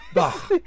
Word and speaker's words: Bach: I'm going Bach. – Bach. – How would you yes. – go? – Bach. So very German.